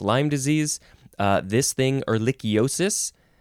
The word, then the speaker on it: ehrlichiosis: This one is ehrlichiosis. Yes, ehrlichiosis.